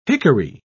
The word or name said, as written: hickory